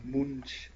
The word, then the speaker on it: Munch: Munch wird es ausgesprochen.